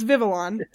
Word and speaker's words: Vivillon: But in other Pokemon news, a Pokeball patterned Vivillon. All this Pokemon stuff is so hard for me to pronounce. It's Vivillon.